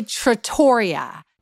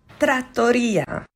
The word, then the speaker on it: trattoria: First, I say trattoria. It's trattoria.